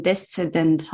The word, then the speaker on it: Deszendent: was ich zum Beispiel für ein Deszendent habe? Ich hoffe, ich habe es richtig also, ausgesprochen. Ja, das ist richtig.